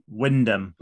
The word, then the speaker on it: Wymondham: Vicky summons her advisors, Sarah, Susanna and Katrina, and says they all come from Wyndham. – But as Pickle rather needlessly points out, it's spelt Wymondham.